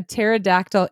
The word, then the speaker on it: pterodactyl: Because it has a silent P. Do you get it? Yeah. So pterodactyl is spelled pterodactyl. So the P in the front of the word is silent.